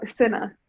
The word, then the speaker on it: Cinna: No, Cinna.